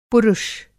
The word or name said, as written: पुरुष